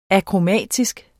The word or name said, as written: akromatisk